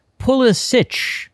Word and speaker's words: Pulisic: Says it's pronounced Pulisic, C-H sound.